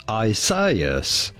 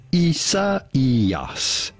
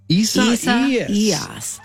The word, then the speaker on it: Isaías: We are looking at how to pronounce this name, even though it might look like it should be pronounced Isaias, because this is a name of Spanish and Portuguese origin from the biblical Isaiah. – Oh. – This is to be pronounced Isaías.